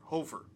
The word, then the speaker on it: Hofer: Blues pull Hofer. Hofer? Hofer? Hofer. I'm gonna say Hofer. Hofer.